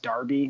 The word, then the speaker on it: derby: And I got to tell you, like, the rivalry here, it's called, so the phrase derby, it's spelled like derby, like Kentucky Derby, but it's pronounced derby. Mm-hmm. It's a British phrase.